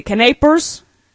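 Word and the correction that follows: canapés: Canapes. – Canapes, and you had roasted rosemary lamb over roasted vegetables.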